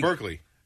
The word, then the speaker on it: Berkeley: Then you have Burke Breathed. Is that how you say his name? – Berkeley. – What's that? – Berkeley Breathed? Yeah.